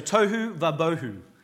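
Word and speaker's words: tohu vabohu (תהו ובהו): So let's say, so tohu vabohu, okay? It kind of, it rhymes.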